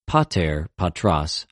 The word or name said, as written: πατηρ, πατρος